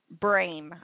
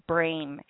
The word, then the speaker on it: Brame: My name, my legal name, is Maria Brame. I will spell that for you. Bravo, Romeo, Alpha, Michael, Echo, Maria Brame.